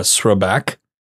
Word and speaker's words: Srbac: That was in uh, Srebak, which doesn't even sound like what I'm saying because it's got an S R B A C. That's how you, that's how it's spelled.